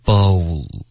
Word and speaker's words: bowl: B-O-W-L bowl OK 所以第一个和第二个一起说 OK bow bowl OK 第二个字第三个字是烧对吗 B-U-R-N